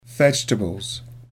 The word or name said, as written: vegetables